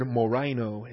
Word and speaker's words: moraino: Finally, the equivalent of tastelessness is the Greek word moraino, M-O-R-A-I-N-O. Moraino is the act of becoming a moron, the act of becoming a moros, or a moron in the neuter, but moraino, M-O, that's the long O, the omega. M-O-R-A-I-N-O. Both the O's in that verse, in that word, are omegas.